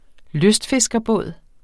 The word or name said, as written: lystfiskerbåd